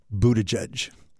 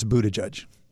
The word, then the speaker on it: Buttigieg: Okay, so first of all, I think it's pronounced Buttigieg. If you look at the spelling, you would never guess any of this. But anyhow, so I think it's Buttigieg.